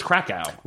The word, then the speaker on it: Krakow: How do you spell it? Is it K-O-W? – I think it's just I think so. – Okay. Krakow. – It's just Krakow. Well, I've been saying it wrong this whole time.